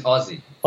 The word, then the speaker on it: Hosi: Actually, it's Aussie. – Aussie. No, we say Aussie, yep, AUSI, but it's spelled HOSI. Yeah.